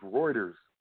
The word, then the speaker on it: Reuters: I know how to say it correctly now, y'all. It's Reuters.